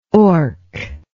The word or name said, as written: orc